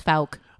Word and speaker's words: Fouke: No, it's Falke. – Okay. – It's got to be, right? – All right. It may be, yeah. – O-U-K-E, Falke, F-O-U-K-E.